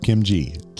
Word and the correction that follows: Kinji: Kinji. Kinji. I really missed it. Yeah, I said it wrong right. – on both sides.